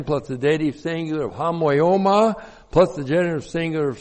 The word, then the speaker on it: homoioma: The preposition en is in, correctly translated in, and then we have a very important word here, homoioma. Looks like that in the Greek, H-O-M-O-I, long O-M-A. Homoioma.